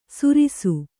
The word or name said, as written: ಸುರಿಸು